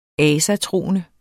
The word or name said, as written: asatroende